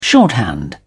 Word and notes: shorthand: British English

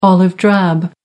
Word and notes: olive-drab: American English

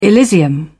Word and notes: elysium: British English

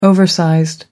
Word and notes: oversized: British English

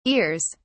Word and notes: ears: British English

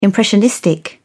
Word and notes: impressionistic: British English

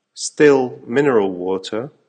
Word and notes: still mineral water: American English

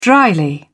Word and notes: drily: British English

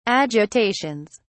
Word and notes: agitations: British English

